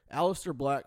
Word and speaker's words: Aleister Black: Aleister Black probably has the most potential, the most. Yeah, that's right. I said that right.